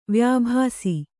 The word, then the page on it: ವ್ಯಾಭಾಸಿ: kannada